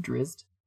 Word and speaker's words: Drizzt: I usually just pronounce the Z's like an S more, so it's just Drizzt.